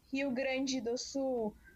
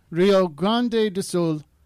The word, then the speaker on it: Rio Grande do Sul: and the state is called Rio Grande do Sul.